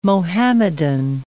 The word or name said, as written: Muhammadan